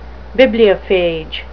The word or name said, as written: bibliophage